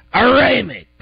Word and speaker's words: Aronimink: That's Aronimink.